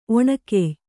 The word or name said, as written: ಒಣಕೆಯ್